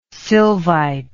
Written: sylvite

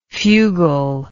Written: fugle

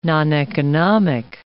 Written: noneconomic

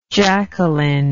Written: Jacqueline

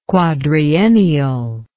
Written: quadriennial

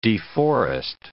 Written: deforest

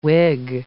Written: wig

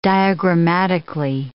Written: diagrammatically

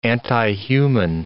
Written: antihuman

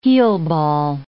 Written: heelball

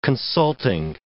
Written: consulting

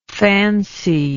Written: fancied

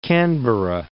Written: Canberra

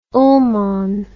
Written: Ullmann

